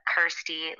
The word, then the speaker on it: Kirsty: Also, thirsty rhymes with my name, Kirsty, like thirsty.